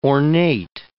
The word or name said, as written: ornate